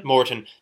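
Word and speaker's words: Maurten: How do you pronounce the brand name? – Moreton.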